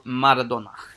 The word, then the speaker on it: Maradona: First and foremost, we want to say rest in peace to the footballing legend, man, Maradona. – Diego Armando Maradona. Yes, I don't know why, man, I pronounced it like that.